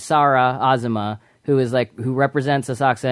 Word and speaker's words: Asakusa: Do you pronounce it Asakusa or Asakusa? That's a good question, I think. – Asakusa.